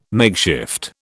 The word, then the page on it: makeshift: British English